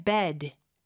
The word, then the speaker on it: bed: 그 다음에 bed, bed. 에 발음보다는 좀 더 약간 눌러주는 느낌의 bed로 해주셔야 돼요. 이게 중요한 게 bad, 나쁜의 bad하고 발음이 좀 헷갈릴 수가 있어요. 그래서 나쁜은 bad, 입을 좀 더 크게 벌려주고 침대는 bed, 에 발음으로 좀 더 눌러주는 느낌으로 해주시면 됩니다. I'm heading to bed, I'm heading to bed.